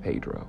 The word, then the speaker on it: Pedro: Yes, it's pronounced Pedro, and if you say it any other way, the locals will laugh at you.